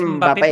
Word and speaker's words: Mbappé: Sí, es Mbappé porque tiene acento en la E, tiene acento en la S.